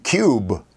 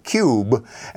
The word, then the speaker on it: Kubb: So that's where the new cube product, and you spell that K-U-B-B. So yeah, it does kind of look, you mentioned in, here in the U.S., some people say cub, because that's what it looks like, but cube, as in a cube.